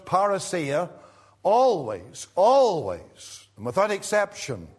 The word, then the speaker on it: parousia: It is the word parousia.